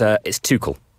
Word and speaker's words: Tuchel: Is that how you pronounce it? My German's not very good. – It's not. No, I'm sorry, it isn't. It's Tuchel. – Tuchel. Okay. Yeah. I've gone a bit French with my pronunciation. Tuchel.